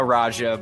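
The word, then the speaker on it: Radja: Dino Rada. – You butchered his name. It's Dino Raja, but yes, that's correct.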